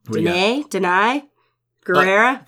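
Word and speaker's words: Danai Gurira: Danai? Guerrera.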